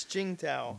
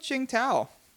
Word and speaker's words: Tsingtao: B is Tsingtao. Tsingtao? You pronounce it better. Yeah. – It's Tsingtao. – Tsingtao. Okay. – Tsingtao. Yeah, that would be correct.